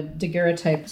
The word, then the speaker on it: daguerreotype: So you don't have to have a daguerreotype studio.